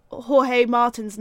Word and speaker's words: Jorge Martin: I also found out we've been saying Jorge Martin's name wrong this whole time.